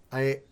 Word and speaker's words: Luloff: Yeah, Luloff. – Okay. – Luloff, I've known you for 5 years. – This is true. Half a decade. – And yes, I have a decade. And I always, whenever I say your last name, I feel like I'm saying it wrong. – No, you got it right.